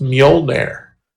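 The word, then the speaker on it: Mjölnir: Meanwhile, there's a spectral hammer floating by you saying, actually, it's pronounced Mjolnir.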